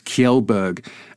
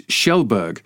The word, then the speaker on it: Kjellberg: and I'd pronounced PewDiePie's name Felix Kjellberg, and apparently it's Schellberg.